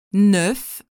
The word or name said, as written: neuf